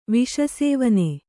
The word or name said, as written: ವಿಷಸೇವನೆ